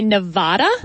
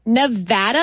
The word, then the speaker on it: Nevada: Because a lot of politicians would say Nevada. – Wrong. – And that is wrong. – It is Nevada. And I had to train myself to say Navapple so I could pronounce the A right.